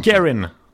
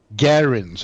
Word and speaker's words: Guerin: Bill Guerin. – Guerin säger man. Nu ska jag komma ihåg det. Guerin. Jag säger Guerin.